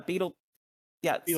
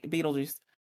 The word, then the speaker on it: Betelgeuse: Beetlejuice. So like, right, Betelgeuse.